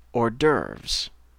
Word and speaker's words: hors d'œuvre: Alors, la prononciation du mot, c'est hors d'œuvre.